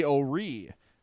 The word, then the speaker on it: O'Ree: Willie O'Ree, 1958, broke the NHL color barrier? Am I saying that right? – Yep.